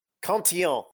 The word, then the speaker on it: Cantillon: Cantillon, how do you say it? – Cantillon.